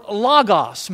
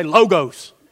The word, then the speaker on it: logos: Now the word that's used for Word here is the Greek word logos. Many people say, because we're down here in the South, we say logos. But it's actually logos. That's how you pronounce it.